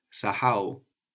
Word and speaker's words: kusahau: K-U-S-A-H-A-U. Kusahau, to forget. How would you say we forget? – Tunasahau. – Tunasahau. So in French, I guess you have a silent H or a very weak H. Mm-hmm. And that's coming across a lot in Swahili. So you want to be careful to pronounce it, to actually pronounce it. Mm-hmm. Sahau. – Sahau.